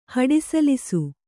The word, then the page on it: ಹಡಿಸಲಿಸು: haḍisalisu